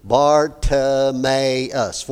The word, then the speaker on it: Bartimaeus: Bartimaeus, that's a pretty long name. Bartimaeus, four syllables.